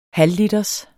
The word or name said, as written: halvliters